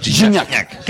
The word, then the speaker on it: Gignac: Ah, así se pronuncia, ¿verdad? – Gignac. Que no Gignac.